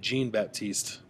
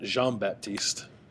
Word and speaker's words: Jean-Baptiste: And then the last guy is Javante, and this is how Greg Schiano pronounced it, Javante Jean-Baptiste, not Jean-Baptiste.